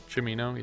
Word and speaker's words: Cimino: Cimino, yeah. It's a CH sound.